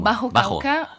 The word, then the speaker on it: Bajo Cauca: Et vous, votre terrain d'exploration, c'est la région de Bajo Cauca? Je prononce mal, excusez-moi. – Bajo Cauca.